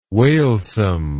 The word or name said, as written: wailsome